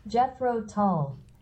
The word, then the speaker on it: Jethro Tull: É assim que pronuncia? Porque teve muitas pronúncias que eu ouvi. É essa a pronúncia? Jethro Tull? É? – Jethro Tull.